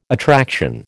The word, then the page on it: attraction: American English